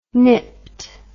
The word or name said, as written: nipped